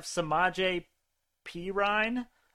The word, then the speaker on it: Samaje Perine: This next one, I have Samaje Perine over 2.5 rushing yards at minus 110. – I love it because I picked up Samaje Perine. Also, props to you for getting the name pronunciation right.